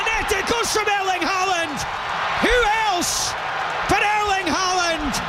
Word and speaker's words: Haaland: Ja, og Erling Haaland, altså det jo, vi må ta en telefon til vår kollega BBC og så si at to A-er på norsk, det uttaler vi Å.